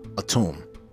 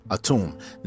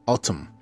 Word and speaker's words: Atum: Autumn comes from the word Atum. Atum. And that's A as an apple, T as in Tom, U as an umbrella, M as in Mary. Autumn or Atum. Sometimes you may see it spelled with an O.